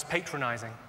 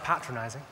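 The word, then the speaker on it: patronising: It's just one came up to me after a show a couple of weeks ago and said he thought I was patronizing. I said, well, I think you'll find that's pronounced patronizing.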